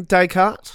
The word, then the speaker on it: Descartes: Do you know how I'm spelling Descartes? – Of course I do. The silent S. – Yes, there's a couple of silent S's. There's Descartes.